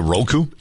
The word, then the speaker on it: Roku: Is it Roku? – Yeah, R-O-K-U is how it's typically spelled.